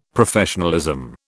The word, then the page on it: Professionalism: British English